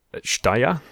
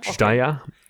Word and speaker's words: Jdahya: Shtaya is the way that the narrator pronounces it. – Okay, so it's spelled J-D-A-H-Y-A, but Chtaya, yeah. – Okay? We'll say... – It's kind of a soft J.